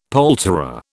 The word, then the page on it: poulterer: British English